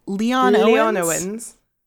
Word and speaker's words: Leonowens: Leonowens. – Yeah, it feels like you've got to say it all at once.